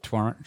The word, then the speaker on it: Toronto: That bit that Chris from Toronto, am I saying it right? Toronto, yeah, fuck that second T, brother. – Yeah, okay, sweet. Toronto.